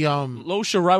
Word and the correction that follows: Io Shirai: Io Shirai.